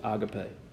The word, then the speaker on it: agape: But the proper pronunciation, if you want to have it right, is agape.